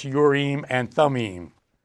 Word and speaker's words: Urim and Thummim: properly pronounced Urim and Thummim. I am is always Im in Hebrew.